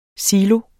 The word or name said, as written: silo